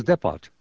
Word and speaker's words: depot: Yeah, I thought it was Depot. – No, you don't pronounce the T.